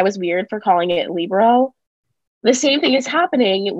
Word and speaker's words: libero: So in Italian, because it's an Italian word, in Italian, they like to place emphasis on the first syllable. – Yes. – Pizza, right? Libero. Not libero.